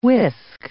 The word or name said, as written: whisk